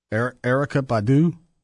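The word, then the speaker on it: Erykah Badu: I don't know how to say this one. Erica Badu? – Yeah, you got it. – Well, it's not spelled like Erica.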